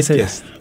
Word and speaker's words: Zeitgeist: Zeitgeist.